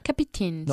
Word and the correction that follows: captain's: Not capi, captain, dos sílabas.